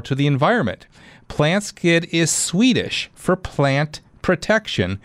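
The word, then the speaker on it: Plantskydd: It's spelled plant SKYDD. So, and you pronounce it PlantSkid.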